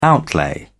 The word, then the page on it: outlay: British English